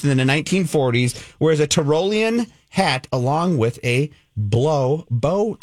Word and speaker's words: Tyrolean: It's pronounced Tyrolean.